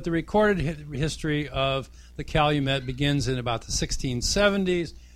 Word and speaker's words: calumet: It's the English pronunciation, like valet is valet in English, and fillet of fish is a fillet of fish. So the calumet is a calumet.